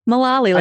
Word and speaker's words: Mullaley: Mullaley, like a lollipop.